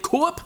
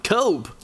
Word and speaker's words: Koepp: K-O-E-P-P. Hvordan vil vi udtale det? Coop? Coop, ja. – Cope. Nå, David Cope, vi siger noget Cope, men, øh, men øh, han har i hvert fald også instrueret en fantastisk film, der hedder Premium Rush, ja.